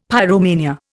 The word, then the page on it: pyromania: Indian